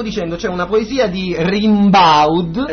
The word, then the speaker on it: Rimbaud: E come si pronuncia? – Fascisti. – Allora si pronuncia Rimbaud. Arthur Rimbaud.